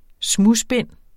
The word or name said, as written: smudsbind